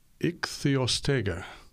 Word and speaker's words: Ichthyostega: Now, I'm going to try to pronounce this. This was, I think, a proposed fossil intermediate between fish and amphibian, and I think it's an ichthyostega. – Yeah, I think you've said that very well.